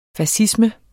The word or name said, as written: fascisme